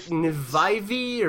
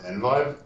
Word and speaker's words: NVIVE: Is it Nvivi, or like what? How do we say that? – So it's pronounced NVIVE. – Okay. Kind of like NVIDIA, that sort of thing.